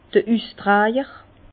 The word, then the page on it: d'r Üstrajer: Bas-Rhin; Herrlisheim